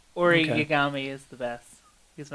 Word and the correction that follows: Iori: Am I pronouncing his name wrong? – Oh, Iori. Yeah, Iori. Yeah.